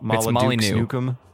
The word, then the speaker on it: Molyneux: It's Molyneux. The X is silent.